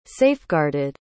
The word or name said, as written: safeguarded